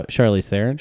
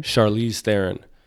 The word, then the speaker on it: Charlize Theron: Charlize Theron. – Charlize Theron.